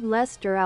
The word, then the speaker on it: Leicester: Lord Leicester aus Manchester. Lord Leicester aus Manchester. – Ja, geht doch. Und in Deutsch? – Lord Leicester aus Manchester.